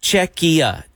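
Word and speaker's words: Czechia: Okay, someone sent in a text, and I'm going to put it in the text song, exactly how to pronounce it. And they said Chechia.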